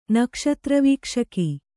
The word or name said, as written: ನಕ್ಷತ್ರವೀಕ್ಷಕ